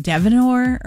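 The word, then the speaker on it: Dynevor: Also, Phoebe, I don't know how to say her last name, Devenor, or what? I always mess it up, but she's in Bridgerton. – That sounds right.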